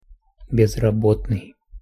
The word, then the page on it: bezrabotnyj: Russian